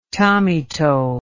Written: tommyto

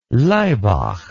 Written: Laibach

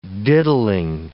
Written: diddling

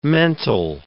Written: mental

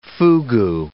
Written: fugu

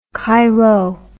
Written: xp

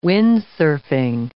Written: windsurfing

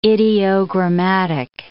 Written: ideogrammatic

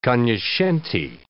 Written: cognoscente

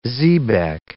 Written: xebec

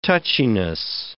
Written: touchiness

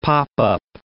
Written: pop-up

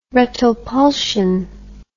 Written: retropulsion

